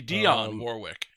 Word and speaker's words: Dionne Warwick: I guess it's Dionne Warwick, yeah. – Wait, it's Dionne? – Dionne? – It's a different guy? – I don't know how to fucking pronounce it. – It would be Dionne Warwick.